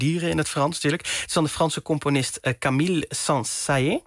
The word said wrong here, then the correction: Saint-Saëns: Zeg ik dat goed? – Nee, je spreekt het uit als Saint-Saëns. – Saint-Saëns.